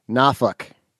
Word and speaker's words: Norfolk: where they don't pronounce the R or the L. So it's Norfolk. Like Norfolk. – Norfolk. Right. – At least in Virginia, they say the R.